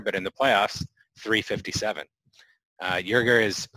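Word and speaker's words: Joerger: They mentioned the Lakers assistant Jason Kidd, the Hawks interim coach and former Blazers head coach Nate McMillan, Philadelphia 76ers assistant Dave, is it Yerger? Did I say that right? I think so, yeah.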